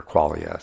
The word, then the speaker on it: qualia: These are qualia, or qualia sometimes pronounced. I prefer qualia because the word is closer to quality, right? So I like to pronounce it qualia.